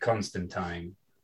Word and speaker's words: Constantine: So when it comes to things like Constantine, rhymes with swine, easy way to remember.